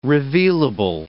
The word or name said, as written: revealable